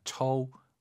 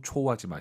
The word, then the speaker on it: 抽: 우리가 처우, 초우, 초우하지 마시고 오유는 어우 발음이에요. 처우, 처우엔.